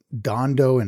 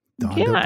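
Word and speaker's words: Dhandho: Manesh calls it Dondo investing, if I'm not saying that wrong. How would you say D-H-A-N-D-H-O, I think? – Dondo. I don't know.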